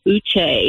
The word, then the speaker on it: Uché: So my first name is pronounced Uche, because I know if I have family members listening, they will be so upset that I didn't correct you.